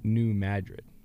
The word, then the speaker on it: New Madrid: And, you know, I didn't know this, but maybe you guys did, that it's a city in Missouri, and they do pronounce it New Madrid.